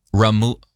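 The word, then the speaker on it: rémoulade: They've also got Rumalad. No, Ramulad.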